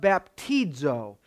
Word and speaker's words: baptizo: It's pronounced baptizo.